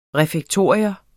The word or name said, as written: refektorium